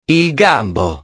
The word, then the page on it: il gambo: italiano